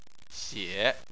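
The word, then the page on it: xie: xie3; tone 3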